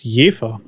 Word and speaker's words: Jever: Die meisten Deutschen sprechen das falsch aus, weil sie den Ortsnamen nur aus der Bierwerbung kennen, wo er falsch gesagt wird. Es ist Jever, ein schöner Ort in Friesland.